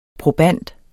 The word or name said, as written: proband